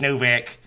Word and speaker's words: Novak: S-T-A-C-H-E. Stache Novak. N-O-V-A-K.